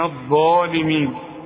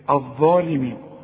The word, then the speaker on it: الظالمين: ما تقولش منا منا من الظالمين، الظا هي المفخمة واعلى درجات التفخيم، احنا قلنا الحرف المفخم اللي بعده الف، فين الالف؟ قال الالف اهي اللي فوق الظا، يكتب القرآن هكذا وينطق هكذا، شوف من الظالمين تمدها حركتين الف كاملة الظالمين، خلاص.